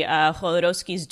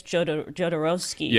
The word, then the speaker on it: Jodorowsky: And also, by the way, if you haven't seen the documentary Jodorowsky's Dune, it's amazing. Highly recommend if you're into that stuff. It's basic. So Alejandro Jodorowsky, I think it's Jodorowsky.